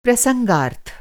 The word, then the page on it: प्रसंगार्थ: Hindi